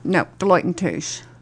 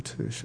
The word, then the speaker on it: Deloitte and Touche: Deloitte and Touche. Did I say that correctly? – No, Deloitte and Touche. – Deloitte and Touche. Okay, Touche. – Touche.